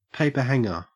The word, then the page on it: paperhanger: Australian English